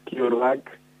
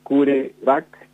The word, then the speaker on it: CureVac: Yo lo dije con la pronunciación en inglés, que es la que usa la mayoría, es CureVac. CureVac, una vacuna que cura, algo así.